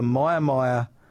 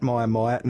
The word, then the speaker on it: Mia Mia: The Myermyer races at Myermyer. Now, it's in northern Victoria. Check it out on the maps. Myermyer is spelled M-I-A-M-I-A.